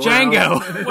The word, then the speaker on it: Django: Django. – Whatever. There's a D in there. – I know there's a D in there. – It's a silent D?